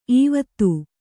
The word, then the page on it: ಈವೊತ್ತು: īvottu